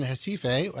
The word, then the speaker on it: Recife: And speaking of which, you are also a pastor, First Presbyterian Church in Recife, and I pronounce that the Portuguese way. – Yeah. Yeah. Well, your pronunciation is quite good.